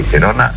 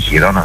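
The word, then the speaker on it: Girona: Ρεκούλ 18 εκατομμύρια η Τζιρόνα ή Χιρόνα, δεν ξέρω πώς. Πώς προφέρεται, δεν το έχω δει. Τζιρόνα είναι, γιατί είναι από την Καταλωνία.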